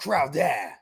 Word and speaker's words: Crowder: Fuck Crowder. – No Chowder. – Yeah, just it's pronounced Crowder. Say it right, Frankie.